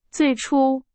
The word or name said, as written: 最初